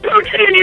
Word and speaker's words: poutine: It's pronounced poutine, you fucking idiot!